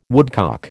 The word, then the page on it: woodcock: American English